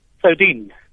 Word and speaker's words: Soudien: Soudien.